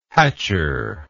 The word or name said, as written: hatchure